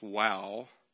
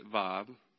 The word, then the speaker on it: Vav: They think back in that time it was pronounced Wow, but today they pronounce it Vav.